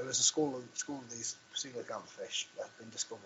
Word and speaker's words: coelacanth: Well, I can never say this properly. There's the prehistoric fish. – Coelacanth. – Coelacanth, that's the one.